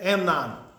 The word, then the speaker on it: Amnon: Amon. I mean, you can say it whatever way you want, but Tamar and Amon, right? Is that how you say it? Amnon.